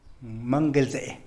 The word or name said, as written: mangelzee